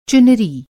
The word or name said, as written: चुनरी